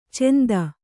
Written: ಚೆಂದ

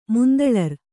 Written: ಮುಂತಳರ್

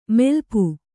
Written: ಮೈಲಾಪ

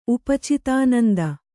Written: ಉಪಚಿತಾನಂದ

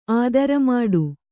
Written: ಆದರಮಾಡು

